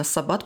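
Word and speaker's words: sabbat: В среднефранцузском слово «саббат», именно «саббат», потому что во французском ударение на последний слог, оно появляется в 13-м, примерно, 14 веке и начинает обозначать не столько субботу, но в целом собрание ведьм в народной традиции.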